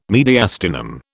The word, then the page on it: mediastinum: British English